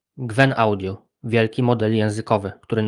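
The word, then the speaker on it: Qwen: Mówimy o Gwen, wymawianym jako Chen Wen, co w języku chińskim oznacza po prostu 1000 pytań.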